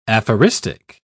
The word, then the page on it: aphoristic: British English